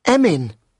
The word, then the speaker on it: Emin: Emin. – How do you spell that? – Emin? That's easy. E-M-I-N.